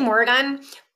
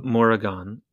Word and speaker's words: Morrigan: I think, yeah, I mean, I say Morrigan, but okay. I would like to say that I am not very good at pronouncing things. I know a lot of words, but I'm not sure how great I am at pronouncing things. – Well, you have a little superflex over the A, so let's call it Morrigan.